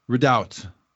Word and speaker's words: redoubt: I didn't know how to pronounce it. It looks like readout to me. I mean, I'm a bloody American, doggone it. But, okay, redoubt.